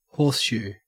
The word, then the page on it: horseshoe: Australian English